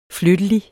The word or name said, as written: flyttelig